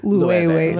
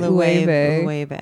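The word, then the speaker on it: Loewe: Lueve. – Lueve. – Like the Spanish word for rain.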